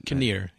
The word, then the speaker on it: Kinnear: Kinnear, yeah. – Kinnear, okay.